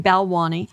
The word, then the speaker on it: Balwani: She was indicted on federal charges along with her ex-boyfriend, Sonny Bilal. Oh, I can never say his name. She was indicted on federal fraud charges along with her ex-boyfriend, Sonny Balwani, and he will face charges later this year.